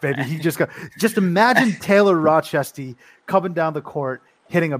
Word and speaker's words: Rochester: Okay, but say it with me now, Rochester. Just the CH, the hard syllables is crucial for me.